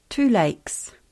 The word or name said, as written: Rotorua